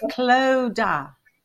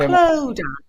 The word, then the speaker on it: Clodagh: it's Claudia. – Clodagh. Okay. I'm still not getting it right. – Clodagh. So well, C-L-O, Clodagh.